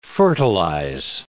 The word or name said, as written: fertilize